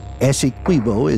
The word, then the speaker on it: Essequibo: Essequibo is pronounced with the Q-U-I like in quit. It's an Amerindian word, and although it looks Spanish, it is not pronounced that way.